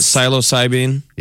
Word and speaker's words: psilocybin: How do you say it? Psilocybin? – Yep.